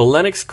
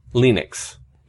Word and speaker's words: Linux: So technically, you should pronounce Linux as Linux, and if you listen to Linus pronounce it, you'll hear him pronounce it that way.